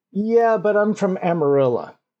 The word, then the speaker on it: Amarillo: And first off, from the fact that I pronounce it that way, that is the correct way to pronounce it. Now, you know this should be amarillo. – Oh, yeah. It's yellow. – But it's no, it's Amarilla.